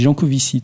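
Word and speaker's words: Jancovici: Alors, je ne sais pas ce que vous en dites, mais alors, sans entrer dans l'excès ni dans la polémique lancée par Giancovici sur un permis de voyager... – Ça ne se prononce pas comme ça. On dit Giancovici, tout bêtement.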